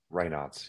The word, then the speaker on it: Raynaud's: Raynaud's syndrome? – Raynaud's, yes.